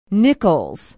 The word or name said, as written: nichols